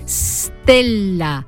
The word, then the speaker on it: stella: En latín se escribía stella y se pronunciaba con S líquida, es decir, stella, un sonido antipático y difícil de pronunciar para nuestros antepasados, que le plantificaron una E protética por delante, una prótesis, una muleta, vaya, una vocal donde apoyar esa E.